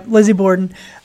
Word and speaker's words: Lizzie Borden: Lizzie Borden. – The Lizzie Borden house. – Or yep. Borden house. – Yeah, Lizzie Borden.